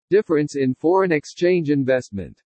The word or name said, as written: DIFFERENCE IN FOREIGN EXCHANGE INVESTMENT